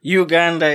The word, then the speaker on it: Uganda: That's a more French intonation of the name. And Uganda is the way it's pronounced.